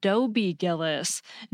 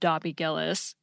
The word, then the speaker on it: Dobie Gillis: It is The Many Loves of Dobie Gillis, not The Many Loves of Dobby Gillis.